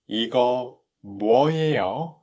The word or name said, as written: party snacks